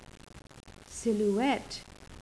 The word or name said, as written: silhouette